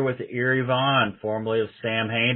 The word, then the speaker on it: Samhain: Is it is it actually Sam Hain, or is or is it like the old Halloween version that like they talk about Halloween movie, the Samhain? – Yeah, well, that's the that's the correct uh, pronunciation, but we always figured it was too confusing for people, so we just went with the um the spelling, I guess.